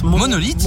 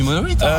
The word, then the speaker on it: Monolith: Je vais peut-être mal le prononcer parce que je suis très nul en anglais. Monolith. – Monolith. Ouais, ça se dit Monolith.